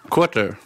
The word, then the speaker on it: Quartr: Quarter. – Jag var så osäker på hur man uttalar det för att det stavas... – Q-U-A-R-T-R. Så quarter på engelska fast ta bort e Vi började som en app och appen slog rätt snabbt så vi skrapade allt content som fanns, finanscontent som bolagen själva producerar.